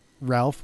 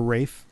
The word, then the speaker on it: Ralph: And Ralph, or Rafe, however you want to pronounce it, Fiennes as Moriarty.